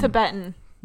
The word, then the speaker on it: Tibetan: There's the um, English um, Tabat Tibetan or I can never say right. Um, Tibetan.